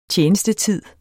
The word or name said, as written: Tjenestetid